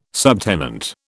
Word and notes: subtenant: British English

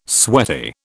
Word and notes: sweaty: British English

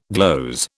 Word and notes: gloze: British English

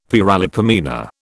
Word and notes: paralipomena: British English

